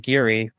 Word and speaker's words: Giri: So you could go into like the soft R territory where it's just Giri. I just have always hated the sound of that.